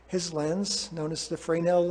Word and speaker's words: Fresnel: In 1822, a French scientist, Augustin Jean Fresnel, it's spelled Fresnel, but I'm trying to learn a little bit of French here, Fresnel, was studying optics and light waves.